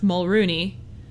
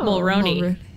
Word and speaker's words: Mulroney: And I learned its last name's pronounced Mulrooney, not Mulroney. – Okay, Mulrooney. – Canadians said that it's Mulrooney.